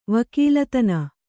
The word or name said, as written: ವಕೀಲತನ